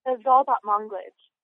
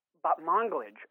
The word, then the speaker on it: Zal Batmanglij: Is it Zal Batmanglij? – Zal Batmanglij. – Batmanglij. Okay. So I was close, yeah. – But not quite.